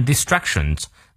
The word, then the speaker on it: distractions: by 双元音念到位，prevailing prevail 重音在后，by prevailing over all obstacles obstacles 重音在前，and distractions distractions 重音在 ch 这个音，one may unfailingly unfailingly 中在第二个音节，fail unfailingly arrive at his chosen，注意它的元音 goal or destination。